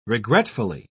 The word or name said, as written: regretfully